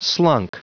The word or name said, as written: slunk